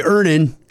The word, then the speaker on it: Earnin: Earnin. – Earnin. – E-A-R-N-I-N is pronounced earnin. I don't know how else you would pronounce it, but they really want it clear that that's how to pronounce it.